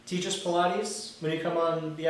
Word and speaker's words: Pilates: So, if anyone likes school us in the art of Pilates...